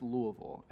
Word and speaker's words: Louisville: That's that way, and yes, it is pronounced Louisville.